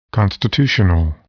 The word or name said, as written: constitutional